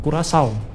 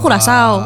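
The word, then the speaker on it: Curaçao: Di sini mah dibilang Curacao. – Hah? Curacao. Oh, bacanya Curacao.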